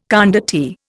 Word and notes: condottiere: Indian

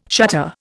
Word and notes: shutter: Indian

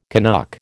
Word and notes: Canuck: American English

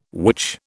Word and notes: witch: British English

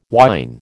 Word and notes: whine: American English

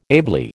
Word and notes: ably: American English

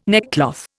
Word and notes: neckcloth: Indian

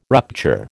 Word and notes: rupture: American English

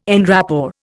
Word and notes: en rapport: Indian

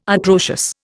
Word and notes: atrocious: Indian

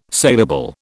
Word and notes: salable: British English

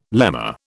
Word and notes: lemma: British English

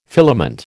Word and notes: filament: American English

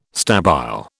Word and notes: stabile: British English

